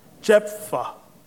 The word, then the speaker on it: Jephthah: Jephthah.